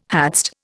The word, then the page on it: hadst: Indian